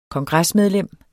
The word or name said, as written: KONGRESSEN